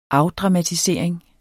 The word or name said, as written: afdramatisering